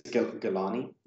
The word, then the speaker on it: Galani: Yeah, I think it's Galani. I think they just the announcers when they pronounce the name, I think they just drop the N on it. It's like a silent N.